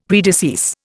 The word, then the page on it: predecease: Indian